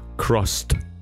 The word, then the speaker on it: crossed: This verb, cross, with the ed ending, is pronounced as t, crossed. Not d, not id, crossed.